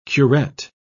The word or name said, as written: curette